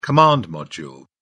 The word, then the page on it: command module: British English